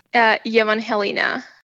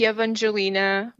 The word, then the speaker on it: Evangelina: Evangelina. I think like it's like Evangelina, more probably okay. American way to say it.